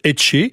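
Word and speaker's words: etxe: la maison qui se prononce Etché.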